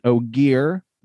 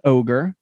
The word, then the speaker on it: Ogier: He is an Ogier, not an ogre.